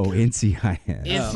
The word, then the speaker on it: NCIS: And then Nikiss, is that how it's pronounced? – NCIS.